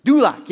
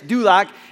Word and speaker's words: Duloc: Like, Durlock or something? Duloc, yes, Duloc.